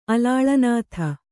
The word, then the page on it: ಅಲಾಳನಾಥ: kannada